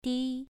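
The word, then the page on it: di: tone 2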